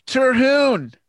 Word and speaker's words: Terhune: Yeah, people in my own family say it differently, so I've settled on Terhune. That's the correct way in my book. – Okay, Brent Terhune.